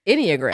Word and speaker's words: Enneagram: Before this, we were like, how do you say it? Enneagram? But it's Enneagram.